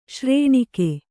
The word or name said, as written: ಶ್ರೇಣಿಕೆ